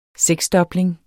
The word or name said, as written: seksdobling